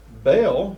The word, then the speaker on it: Baal: Baal, B-A-A-L, or Baal, as some pronounce it, is a Semitic word signifying the Lord, master, owner, keeper, husband.